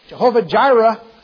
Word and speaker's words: Jehovah Jireh: You find out who God is, Jehovah Hira, Jehovah Jireh, sometimes pronounced.